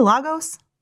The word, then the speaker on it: Lagos: Is that how you say Lagos? – Lagos, yeah.